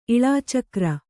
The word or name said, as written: ಇಳಾಚಕ್ರ